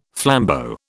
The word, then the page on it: flambeau: British English